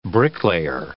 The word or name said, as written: bricklayer